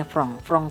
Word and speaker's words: franc: Itu bacanya franc Swiss.